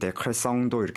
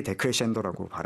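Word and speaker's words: decrescendo: 근데 이제 프랑스 사람들은 이제 자기네들 식으로 발음을 많이 하잖아요. 그래서 마치 철자만 보면은 데크레성도 이렇게 발음할 것 네. – 같은데 정확하게 데크레센도라고 발음을 이탈리아계답게 합니다.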